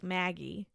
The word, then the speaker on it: Maggi: But it's spelt M-A-G-G. Yeah, you just spelt it. – Yeah. She's just taken one sip. – Mm-hmm. It's spelt M-A-G-G-I, which like looks like Maggie. – Yeah. So I think that's what it's called in English, but we're going to call it Muggy because that's what we've always called it.